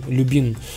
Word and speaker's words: Lubin: Это, я рассказывал это духи а, от компании Любен, э, пишется как L-U-B-I-N, Любин.